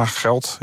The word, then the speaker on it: Gelt: Want die nieuwe munt, die heette Geld. Spreek je dat goed uit? – Ja, Geld. Ja. G-E-L-T. – G-E-L-T, ja.